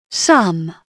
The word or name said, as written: sum